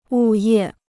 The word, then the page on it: 物业: wù yè